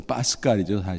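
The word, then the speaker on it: Pascal: 프랑스 사람들은 좀 경음을 발음해요. 그래서 파스칼이죠, 사실은.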